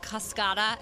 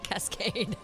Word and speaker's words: Cascada: By the way, I was yesterday years old when I found out it is pronounced Cascada and not Cascade.